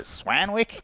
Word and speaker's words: Swanwick: My last name is spelled S-W-A-N-W-I-C-K. It's pronounced Swanwick, but Americans never get the pronunciation right. You got it right, actually. You said James Swanwick. – Swanwick. – But most Americans don't understand that silent W, so they were like, is it Swanwick? Is it Swanwick? I'm like, it's Swanwick. And then I just gave up.